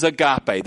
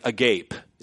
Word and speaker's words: agape: This is agape, this is God's love. And so, but it's spelled A-G-A-P-E in English, you know, if you translate, and it looks like agape. It's like, who are you, agape?